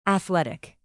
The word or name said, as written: athletic